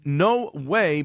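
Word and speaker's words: No Güey: And then No Way, but it's spelt in the Mexican slang term of G-U-E-Y.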